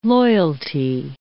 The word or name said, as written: loyalty